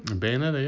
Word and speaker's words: Bayonetta: Bayonetta 2. – Bayonetta, yeah.